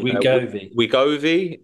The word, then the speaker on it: Wegovy: Wegovy jab.